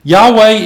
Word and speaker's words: Yahweh: And so now we have this word that's spelled out, YAHWEH. And for the most part, the majority of people are going to pronounce it as Yahweh.